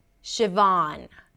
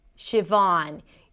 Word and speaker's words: Siobhan: And this is how you pronounce it. Siobhan. Siobhan. You start with sh, sh, like ship, sh. And then the next part is Vaughn. Vaughn. A V sound, ah, as in father, or you can even round the lips a little bit, and then an N.